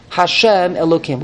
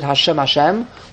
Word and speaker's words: Hashem Elokim: the pronunciation is Hashem Elokim. We don't pronounce it Hashem Hashem. We pronounce it Hashem Elokim.